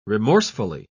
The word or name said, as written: remorsefully